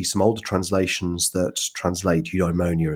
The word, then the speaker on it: eudaimonia: So a word that looms large in Aristotelian virtue ethics is the word eudaimonia, and that's spelled E-U-D-A-I-M-O-N-I-A, eudaimonia.